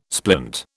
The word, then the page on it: splint: British English